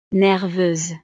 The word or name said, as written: nerveuse